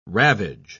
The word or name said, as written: ravage